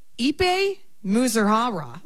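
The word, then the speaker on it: Ippei Mizuhara: On paper, Ipe Muzuhara.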